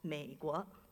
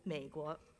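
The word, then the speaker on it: Meiguo (美国): In the Mandarin Chinese language, the United States of America is called Meiguo, just two syllables, Meiguo. And translated literally, that means the beautiful country.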